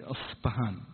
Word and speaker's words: أصبهان: فأظنها يعني تنطق يعني أصبهان زي البي في اللغة الإنجليزية، أصبهان، زين، تصير يعني ما هي باء.